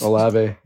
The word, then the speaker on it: Olave: And I just completely skipped over Olave, is that how you say it? – Olave, yeah.